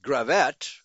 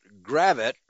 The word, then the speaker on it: Gravette: Correct my pronunciation, I've always been pronouncing it Gravette. But the locals pronounce it Gravette with the accent on the first syllable.